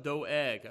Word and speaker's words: Doeg: And he inquired, I did look up Doeg, so that is, that is not a guess on how you pronounce it.